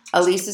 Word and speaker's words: Ilise: Elise is spelled I-L-I-S-E, or pop in your earbuds and listen to this podcast anywhere you listen to your favorite podcasts.